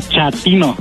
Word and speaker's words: chatino: Chapino. – ¿Chapino? – No, chatino. – Es como chato, ¿verdad? Chatino. – Sí, así me era como chato, chatino.